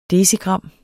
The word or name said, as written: decigram